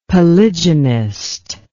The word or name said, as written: polygynist